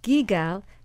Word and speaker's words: Guigal: And then there's another wine, a Cote de Rhone, and it's Gigal, and it's spelled G-U-I-G-A-L.